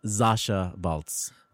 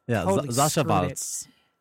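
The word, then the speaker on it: Sasha Waltz: I am, but I have to correct a pronunciation on something because I was corrected on it earlier, and I might as well pass along the pretentiousness. It's Shasa Waltz.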